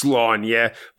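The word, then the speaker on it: Sláine: Slain is, of course, pronounced Slaunya, but I'm not doing that.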